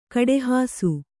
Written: ಕಡೆಹಾಸು

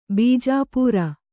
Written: ಬೀಜಾಪೂರ

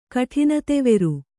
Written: ಕಠಿನತೆವೆರು

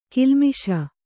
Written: ಗಲ್ಲಿಮೀಸೆ

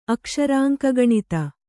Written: ಅಕ್ಷರಾಂಕಗಣಿತ

